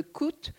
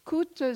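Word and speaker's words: T'kout: Raymond, euh, Messager, le sous-lieutenant, est affecté au poste de coûte coûte ça s'écrit T apostrophe K-O-U-T.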